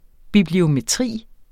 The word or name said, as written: bibliometri